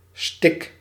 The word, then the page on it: mince: France; [mɛ̃s]